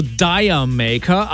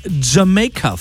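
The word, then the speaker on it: D'yer Mak'er: Man könnte meinen, der Songtitel, der wird irgendwie ausgesprochen so Dire Maker. Aber eigentlich ist es nur ein Wortspiel, so ein englisches. Und der Songtitel ist Jamaica von Led Zeppelin.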